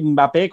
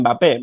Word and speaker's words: Mbappé: Eh, hay gente que le llama eh, Mbappé, como la, M se oye muy poco, pero sigue sonando. Y otros que dicen Mbappé.